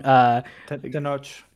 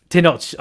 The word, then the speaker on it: Tenoch: Tenoch. – Tenoch.